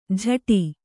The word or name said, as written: ಝಟಿ